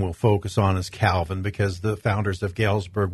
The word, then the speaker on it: Calvin: So a little bit later, not too much later, but in 1536, a man named John Calvin, and that's not how he actually pronounced or spelled his name. He was a French theologian. John Covan probably was the way he pronounced his name, authored the Institutes of the Christian Religion.